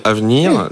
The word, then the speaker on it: Avneer: Alors sur un autre registre évidemment, euh, l'application Avenir, donc je rappelle, ça s'écrit A-V-N-E-E-R.